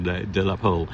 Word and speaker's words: de la Pole: The French pronunciation, de la Pole.